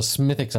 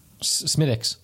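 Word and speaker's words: Smithwick's: Get a little Smithix up in there. Smithix. – Well. – Schmittix. – It spells like Smithwicks, but it's not pronounced that way.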